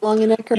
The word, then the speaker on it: Longenecker: Dwight Longenecker.